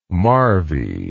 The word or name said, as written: marvy